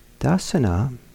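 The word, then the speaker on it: dassana: Dasana is the Pali pronunciation of a word that some of you probably have heard in English, in the certain Indian religious scenes, darshan.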